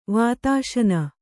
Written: ವಾತಾಶನ

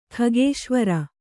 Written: ಖಗೇಶ್ವರ

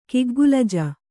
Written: ಕಿಗ್ಗುಲಜ

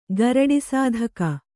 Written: ಗರುಡಿಸಾಧಕ

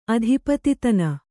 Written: ಅಧಿಪತಿತನ

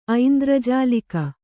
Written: ಐಂದ್ರಜಾಲಿಕ

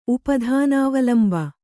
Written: ಉಪಧಾನಾವಲಂಬ

